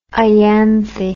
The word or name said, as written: ianthe